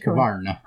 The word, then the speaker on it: Husqvarna: You have to say it correctly, though, I think. – Well, what's what was it? – Husqvarna. – Oh, right. So I mean, it is Swedish.